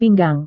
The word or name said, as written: pinggang